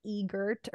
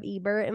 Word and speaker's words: Ebert: I just always think that I say like Egert or something instead of Ebert, and I'm like, I never actually looked at what their name is.